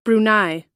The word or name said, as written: brunei